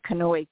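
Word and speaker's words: Kanoe: Your on Facebook, I'm better on Facebook than anything else, and it's on my name on Facebook is Kanoi K, just like the book.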